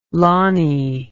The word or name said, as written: Lonnie